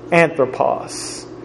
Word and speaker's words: Anthropos: it's A-N-T-H-R-O-P-O-S. Anthropos. Spelling test to follow tonight. It's again A-N-T-H-R-O-P-O-S.